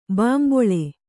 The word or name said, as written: ಬಿಂಬಲಿ